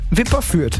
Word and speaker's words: Wipperfürth: Habe ich richtig gesagt? Wipperfürth. – Wipperfürth. – Ist richtig.